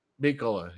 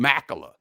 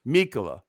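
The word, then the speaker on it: Mikkola: I mean, I worry about that sixth defenseman, but between Ben Harper and I keep getting this guy's name wrong. Nico Mikula? – Mikula, yeah. – I keep calling him Makula. Mikula.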